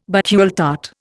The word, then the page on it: Bakewell tart: Indian